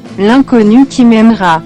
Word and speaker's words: L'inconnu qui m'aimera: Yeah, before Neighbors Burning Neighbors, Jeanette with Lingoku Kimiamera. I guess that's how it's pronounced. Help me out, Google Translate. Do it, would you? – L'inconnu qui m'aimera. – Totally different than what I said.